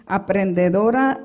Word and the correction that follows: emprendedora: I don't know if I said that right. – Emprendedora.